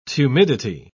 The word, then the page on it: tumidity: British English